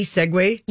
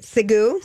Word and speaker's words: segue: Is that correct? S-E-G-U-E, Segway. – Yep. – That's the right way to say that. How do you think people sometimes say it? – Segoo. – Segoo. – Or Sieg. Or seek, you know, thinking it's one, but it's Segway. – Segway. – But it's S-E-G-U-E.